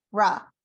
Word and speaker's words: Feyre: Feyre.